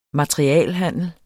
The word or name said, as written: MATERIALHANDLER